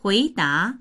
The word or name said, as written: hui2da2